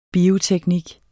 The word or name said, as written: bioteknik